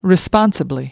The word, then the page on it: responsibly: British English